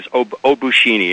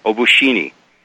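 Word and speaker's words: Obucheni (обучение): But the final word is Obucheni, and it's spelled O-B as in boy, U-C-H-E-N as in Nancy, I-E, Obucheni.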